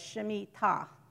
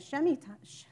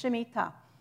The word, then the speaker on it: Shemitah: Okay, in Hebrew, contrary to how the Spanish people speak, the emphasis is on the last syllable. So it's Shemitah. A lot of people are saying Shemitah. I can't even say it in the Spanish way, but most people say it with the wrong emphasis. It's Shemitah. Some people shorten and go Shemitah, Shemitah.